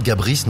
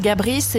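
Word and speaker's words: Gabris: Gabrice, hein, avec le S qui se prononce. – Les Français disent Gabri en général, ça doit vous énerver ou vous laisser couler?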